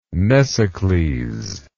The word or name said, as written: mnesicles